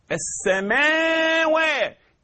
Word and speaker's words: السماوات: رب السماوات، السماوات. إذن هنا الألف الخنجرية اللي بعد الميم اللي بعد التاء تقرأ السماوات.